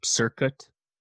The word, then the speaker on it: Cirkut: Yeah, she has this working relationship with this producer, Circuit, I think is how you say it, C-I-R-K-U-T, who's a disciple of Dr. Luke.